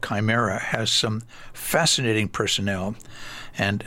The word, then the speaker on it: Chimera: It's one of those things that you are challenged to pronounce it correctly, because it looks like it should be Chimera or Chimera or whatever, but it's actually, according to two dictionary references I checked, it's Chimera.